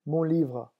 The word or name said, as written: mon livre